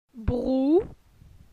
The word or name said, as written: Brou